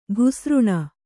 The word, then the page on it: ಘುಸೃಣ: kannada